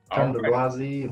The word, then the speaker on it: Thandulwazi: Did I say that right? – Yes, it's correct, sir. Thandulwazi Math and Science Academy.